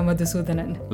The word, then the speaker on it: Madhusudanan: It's a pretty good pronunciation, Anagha Madhusudanan.